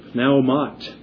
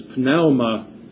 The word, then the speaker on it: pneuma: We would spell it P-N-E-U-M-A-T. It shows up also as pneuma, not pneuma. There are no silent letters in Greek. The Greeks didn't know about that. It's pneuma or pneumat.